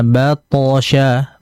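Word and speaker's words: بطش: Yang pertama itu tadi di ayat nomor 12, pantulan huruf to-nya tidak dipantulkan. Nah, jadi hati-hati. Inna batosha, batosha, batosha dipantulkan.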